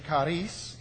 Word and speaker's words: charis: You know, this is grace, and it is pronounced, to the best of my ability, charis in Greek, all right?